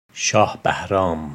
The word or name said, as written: Shah Bahram